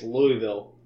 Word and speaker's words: Louisville: Well, we got no line on sticking with Florida State playing against Louisville. Sorry, Louisville is how they pronounce it.